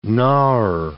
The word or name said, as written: gnawer